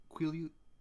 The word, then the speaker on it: Quileute: Then I'll say it Quileute. I don't know how. I don't know exactly how it is. – Quileute. It was a Ute. It was a Ute sound at the end.